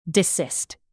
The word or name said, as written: desist